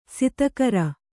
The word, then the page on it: ಸತ್ಕಾರ: satkāra